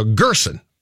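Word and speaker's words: Gersson: And by the way, the correct pronunciation is sent out by the Timberwolves on their press release on Friday. Is phonetically uppercase GR, lowercase SIN. So Gerson.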